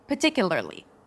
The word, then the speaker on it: particularly: Now, if you struggle with the R sound, you can drop the first R in this word and just say particularly, particularly. That's also totally fine um, and a little easier.